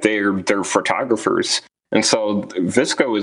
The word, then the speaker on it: VSCO: And it's pronounced Visco, but it's the one that it's VSCO in all caps as the app name.